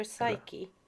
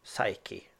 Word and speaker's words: Psyche: Jag tror det är P-S-Y-C-H-E. Psych. – Killer Psyche. – Psyche. Okay. – Psyche, ja, vad bra.